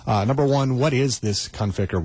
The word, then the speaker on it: Conficker: And some people pronounce it Conflicker. And it's not. It's Conficker. There's no L in there.